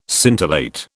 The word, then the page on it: scintillate: British English